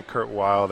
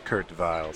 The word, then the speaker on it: Kurt Weill: We're calling him Kurt Weill. That's the American pronunciation, and then the European pronunciation is Kurt Weill.